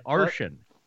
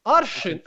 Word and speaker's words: arshin: An arshin. – Arshin. Oh, yeah. Okay. I guess now you know what I'm talking about. Jeez. – You can't pronounce Russian. Not on your own. – Arshin. How's that? Close.